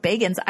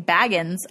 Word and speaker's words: Bagans: I'm no Zach Bagans, which I was so disappointed to find out his last name is pronounced Bagans. I've always been saying Baggins, and I'm like Bilbo Baggins, and I'm like, yeah. Zach, I'm Zach Bagans. I'm going on an adventure. But that's not how you say his name.